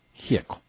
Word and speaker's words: Gieco: A pesar de que su apellido es de origen italiano, en Argentina se pronuncia simplemente Gieco.